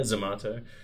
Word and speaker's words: Zomato: I always get Zomato. Is that how you say it? Yeah, Zomato.